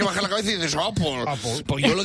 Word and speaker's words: Apple: Apple también mal, porque la E final, tenemos un problema con las S en Spain, porque la E final también debería ser una E más cerrada, no se dice Apple. – Se dice Apple.